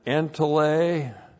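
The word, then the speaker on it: Entole (ἐντολή): And then entole, that's our word commandment. E-N-T-O-L, long E. Entole, referring to the 10th commandment, you shall not lust. And then the verb erkomai, E-R-C-H-O-M-A-I, Erchomai.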